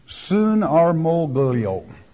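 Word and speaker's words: synarmologeō: It's really kind of an unusual Greek word, and it's only used two times in the New Testament, synarmoglio. That's how it's pronounced, roughly.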